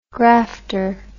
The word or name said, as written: grafter